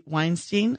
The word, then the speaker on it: Weinstein: Honoring Harvey Weinstein.